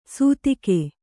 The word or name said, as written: ಸುತ್ತಿಗೆ